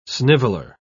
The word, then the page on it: sniveler: English; British English